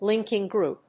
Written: linking group

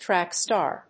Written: track star

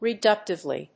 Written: reductively

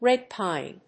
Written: red pine

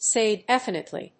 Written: say definitely

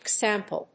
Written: cXML

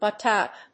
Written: Butt out

